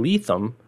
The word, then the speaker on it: Lethem: He reminded me that his pick from the audiobook section was Motherless Brooklyn by Jonathan Lethem.